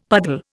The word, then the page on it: puddle: Indian